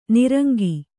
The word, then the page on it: ನಿರಂಕ: kannada